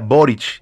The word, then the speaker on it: Boric: los apellidos de origen croata terminados en C se pronuncian con Che. Así sonaría Boric, igual que pronuncias Novak Djokovic.